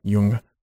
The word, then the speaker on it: Jung: Is it Young or Jung? How do you say it? – Jung.